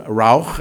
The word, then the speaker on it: ruach: The word spirit, as it is used in this particular case, is a Hebrew word, rauch, and I'm probably pronouncing that wrong, R-U-A-C-H, as it's transliterated into English, ruach or something like that.